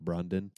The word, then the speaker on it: Brandon: And so fucking Ben Stiller's character calls him Brandon. He's like, actually, no, it's pronounced Brundon.